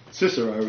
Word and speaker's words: Cicero: Now, I'm going to stop right there for a minute, because Cicero is actually Cicero. It sounds very harsh, but I'm following Prime Minister Gough Whitlam's pronunciation of the hard C as opposed to the soft C, Cicero, in common use today. The hard C as in chorus, all right?